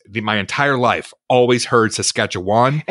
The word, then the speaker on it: Saskatchewan: The proper way to pronounce, as I said it, Saskatchewan, is Saskatchewan. Saskatchewan. Am I saying it right? Saskatchewan. That's what he says here. But if you're from here, usually it just gets shortened to Saskatchewan.